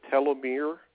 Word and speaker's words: telomere: Another one is, this is where I might mispronounce it, the telomere shortening.